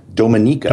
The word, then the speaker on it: Dominica: It's simply where you put the emphasis on the syllable in Dominica.